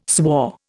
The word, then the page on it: swore: Indian